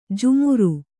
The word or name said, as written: ಜಾಮಾರು